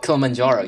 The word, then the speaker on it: Kilimanjaro: Kilimanjaro, yeah, that's how you pronounce it.